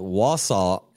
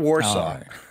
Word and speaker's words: Wausau: Wausau, is that how I get it? Wausau? – That's correct. Not Warsaw. Right. – Not Warsaw. Not Wausau, but Wausau. Okay, good.